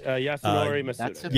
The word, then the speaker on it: Yasunori Mitsuda: Yasunori Mitsuda.